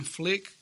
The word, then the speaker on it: Phlick: I have my own personal YouTube channel called I Am Phlick. It's I-M-P-H-L-I-C-K. Don't think that my name is spelled like F-L-I-C-K. It's spelled P-H-L-I-C-K.